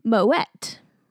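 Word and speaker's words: Moët: The correct way to pronounce it is Moet. – Really? – Yeah, Moet. Apparently they're in the actual spelling. There's the little like two dots above the E making the wet sound.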